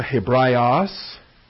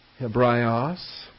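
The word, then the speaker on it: Hebraios (Ἑβραῖος): The Greek term Hebraios. Hebraios. Hebraios. The rough breather there. This would be wonderful, excellent work for our first-year Greek students. You're learning the rough breathers and smooth breathers and some of the pronunciation of these terms.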